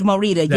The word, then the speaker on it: Morita: Yeah, I've been saying this, Morita. That's how you say it. Yes, Morita, yes.